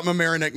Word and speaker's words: Mamaroneck: And so that's the only thing I know about Mamaroneck, New York.